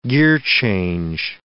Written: gearchange

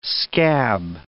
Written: scab

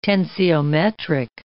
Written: tensiometric

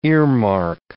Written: earmark

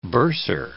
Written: bursar

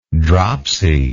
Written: dropsied

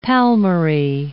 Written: palmary

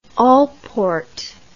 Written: alport

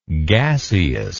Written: gaseity

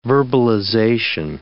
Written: verbalization

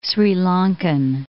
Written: Sri Lankan

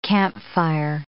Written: campfire

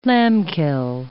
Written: lambkill

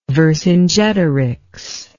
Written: vercingetorix

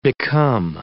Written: become

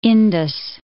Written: Indus

